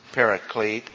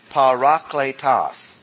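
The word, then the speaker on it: parakletos (παράκλητος): The word is not pronounced paraclete. It's parakletos. P-A-R-A-K-L-E-T-O-S. A-T-O-S. Parakletos. I'll put it two-part. Letos. Parakletos. A lot of times words in the original language gets anglicized and they come across with parakletos.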